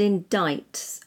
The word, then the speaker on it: indict: So D-U-M-B, dumb. D-E-B-T, debt. S-U-B-T-L-E, subtle. – And so forth. – And so forth. – Silent C in the word indict, I-N-D-I-C-T. And that means to formally accuse or charge someone with a crime. – And my favourite, silent C-H. In Y-A-C-H-T.